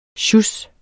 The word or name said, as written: sjus